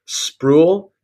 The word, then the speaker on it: Spruill: You know, it is kind of a strange, people are tempted oftentimes to switch the R and the U, so it's Spruill, or some people are tempted to make it multiple syllables, so then it's Spruill, but you're really just one syllable, it's just Spruill.